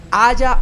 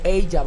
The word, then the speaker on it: Aja: Está dirigida por Alexandre Aya o Ella, porque su apellido se escribe a j -A.